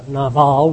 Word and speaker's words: Nabal: But in this particular story, we have a man by the name of Nabal, or Nabal as we usually pronounce it. Nabal would be more proper.